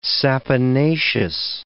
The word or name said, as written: saponaceous